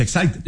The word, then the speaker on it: Exide: Se escribe Exide, se pronuncia Exide, dentro decía la...